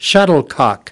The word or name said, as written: shuttlecock